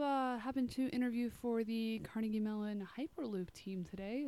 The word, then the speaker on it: Carnegie Mellon: I just want to correct Carnegie Mellon. – Thank you. Yeah, that's the right pronunciation.